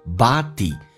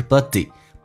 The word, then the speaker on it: body: Everybody，打开口腔啊，美式发音比较夸张，body。记住那个 D 是一个长元音哈，它不是短元音，不是 buddy buddy D E 这个发音。